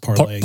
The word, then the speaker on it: Parler: Well, Parler, what they use should be pronounced parlay. – Parlay, yeah. – It's a French word, but they call it parlor.